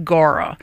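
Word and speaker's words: Gaura: Gara. Rhymes with Laura, but you've got to have you some Gara, some whirling butterflies.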